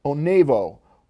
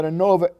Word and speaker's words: Onavo: This is called, I believe it's pronounced Onavo, O-N-A-V-O, Extend.